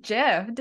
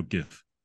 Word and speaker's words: GIF: Like with a J or a G. – Then everyone will be confused. No, it's G. It's spelled G I F.